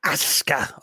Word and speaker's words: Asuka: Asuka.